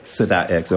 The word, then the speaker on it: Sadat X: Is that the proper pronunciation? – Sadat X. – Okay. – Yep.